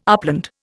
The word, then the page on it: upland: Indian